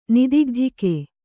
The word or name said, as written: ನಿದಿಗ್ಧಿಕೆ